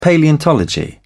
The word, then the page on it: paleontology: British English